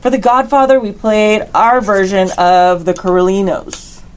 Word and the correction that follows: Corleone: Did I say that right? – Corleone's. – Corleone's. There you go.